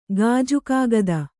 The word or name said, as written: ಗಾಜುಕಾಗದ